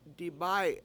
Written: dibAy7

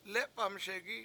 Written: lEpamSegi7

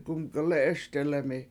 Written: gumgelE7eSdelemi

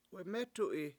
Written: wemEtu7i

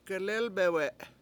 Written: gelElbewe7

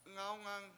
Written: ngAwngang

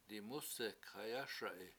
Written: dimUsek7hayASa7i

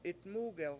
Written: 7itmUgel

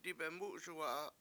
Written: dibemUUSuwa7a

